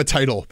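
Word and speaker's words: Teitel: Their national columnist, Emma Title. I may be getting her pronouncing her last name wrong. – She's my first cousin. It's Emma Title, but please proceed.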